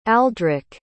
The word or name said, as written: aldric